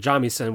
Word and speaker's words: shamisen: The Tsugaru shamisen, which is often pronounced Tsugaru jamisen, which is what I'll probably say,